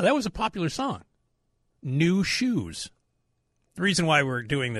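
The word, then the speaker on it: Nu Shooz: It's by a band called New Shoes. But it's N-U-S-H-O-O-S or something. They spell it weird.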